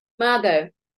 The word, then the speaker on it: Mago: In 2014, Glenys co facilitated the, is it MAGO? – MAGO.